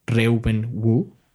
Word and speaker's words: Reuben Wu: Por ejemplo, yo hay un fotógrafo que me gusta mucho, lo recomiendo que busquen su trabajo, se llama Reuben Wu tal cual, así se pronuncia, ha tomado fotografías para National Geographic.